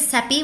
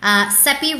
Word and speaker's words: Seppi: My name is Seppi, one of the co-founders here. Seppi rhymes with Peppy is what I like to tell people.